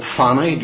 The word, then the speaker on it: Fawney: My mother got saved in the 30s, again down in Fermanagh, in a series of meetings held in a little crossroads, really, called Fanny, just outside of Lisnaskee. It's spelt Fawny, F-A-W-N-E-Y, but I suppose we all corrupt these names, and it was just called Fanny.